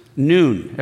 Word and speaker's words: noon: It is pronounced noon. Everybody say noon. So it's the letter N.